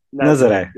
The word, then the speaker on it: Nazaré: How is it pronounced? – Nazaré.